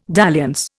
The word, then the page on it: dalliance: Indian